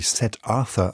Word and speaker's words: Siddhartha: Bücher wie Der Steppenwolf oder Siddhartha, das im amerikanischen Englisch wie Seth Arthur ausgesprochen wird, haben in den USA einen regelrechten Hesse-Kult ausgelöst.